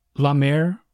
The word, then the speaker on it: la mer: La mer, for the sea, you'll help me with my pronunciation here, and la mer, is there a difference for mother? – There isn't. It's the same sound.